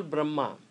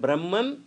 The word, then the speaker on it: Brahma: So now we have got two words, Brahma with short A and another Brahma with long A. Brahma with a short A, that sometimes is said Brahman or Atman to avoid confusion.